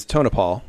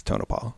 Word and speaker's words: Tonopah: the guy was saying it wrong, and she said, it's Tonopah. And so I was thinking it or saying it wrong myself.